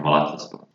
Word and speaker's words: Malatyaspor: Did I get the pronunciation right? – Right, yeah, Malatyaspor.